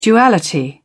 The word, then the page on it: duality: British English